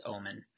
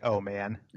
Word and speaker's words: Oman: And underneath Saudi Arabia is Yemen, and right next to it is Oman. And above that is United Arab Emirates. It's above that. – Can I just say how proud I am? He didn't say, "Oh man," pronounce it that way.